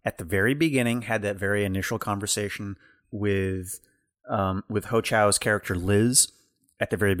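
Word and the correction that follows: Hong Chau: And Ho Chow? – Hong Chow. Sorry.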